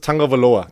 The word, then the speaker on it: Tagovailoa: Es wird mit diesem N. Warum wird es mit diesem N ausgesprochen? Ich verstehe es nicht. Es ist Tagovailoa. Das sagen alle Amis. Das sagt er selber.